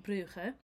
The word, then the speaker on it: Brugge: But yeah, so we are going to be in Bruges, and the Dutch / Flemish pronounce it Brugge, and the French pronounce it Bruges. And I would say Bruges is the most common pronunciation that most people would hear.